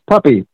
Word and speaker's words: Puppe: It's Puppe, just like puppy dog.